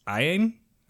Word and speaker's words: Iain: The kid that plays young Sheldon, and I have a hard time saying his name, it's like, it's spelled I-A-I-N, so I guess it's Ian,